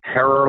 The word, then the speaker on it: Herer: Herer like terror.